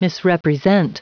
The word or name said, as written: misrepresent